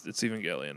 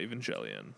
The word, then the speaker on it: Evangelion: I always forget if it's even it's Evangelion, not Evangelion. – Evangelism. – I always forget. I think that's why I always wanted to say it Evangelion because it sounded like evangelical. – Evangelion.